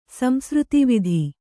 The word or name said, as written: ಸಂಸೃತಿವಿಧಿ